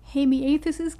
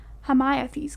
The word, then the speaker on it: Hamiathes: For the gift, I've always said it Hamiathus' gift. Just, I don't know why I chose to pronounce it that way, but Steve West pronounces it rhyming with Hermione's gifts. It's Hamiathes' gifts.